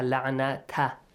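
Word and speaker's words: لعنة: You should read it with fatha la'nata, not na'natu.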